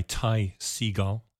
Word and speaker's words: Ty Segall: I see it's produced by Ty Segall.